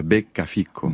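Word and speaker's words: beccafico: Sarde a becca fico. – Becca fico. – C'est ça, l'accent tonique est sur becca. – Puisqu'il y a, c'est très bien, vous avez très bien prononcé.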